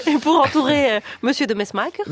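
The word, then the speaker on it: De Mesmaeker: Bah, moi, je disais de Messmaker. – Ah, oui. Comme tous les Français. – Et alors, en Belgique, c'est de Messmaker.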